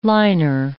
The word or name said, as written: liner